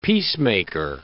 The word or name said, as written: peacemaker